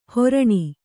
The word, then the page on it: ಹೊರಣಿ: horaṇi